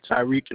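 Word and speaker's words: Tyrieq: Tyreek Norales.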